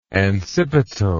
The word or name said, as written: ancipital